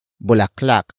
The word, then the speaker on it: bulaklak: And that is bulaklak.